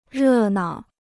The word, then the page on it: 热闹: rè nao